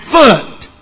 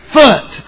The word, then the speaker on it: Phut: P-H-U-T. I pronounce that Phut with a silent, the P and the H being like Philadelphia. Sound like an F? Silent L. If it's not that, it's Phut. If it's not that, it's P-Hut. I don't know, but I believe it's Foot. I believe that's what it is.